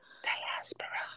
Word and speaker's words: diaspora: Diaspora. – Thank you, diaspora. I don't know why I always say that wrong.